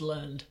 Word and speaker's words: learned: And he, like, it's pronounced learned. – So. Yeah. – You're not impressing anybody with your extra syllable there.